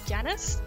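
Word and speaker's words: Janus: I'm going to be playing the character archetype of Janice. That's how you pronounce it, right? – Yeah, close enough.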